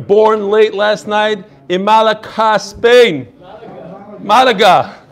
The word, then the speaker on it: Malaga: Malaka. – Malaga. So is that wine also Malaka? Kedem Malaka? Malaga. No, that's Malaga.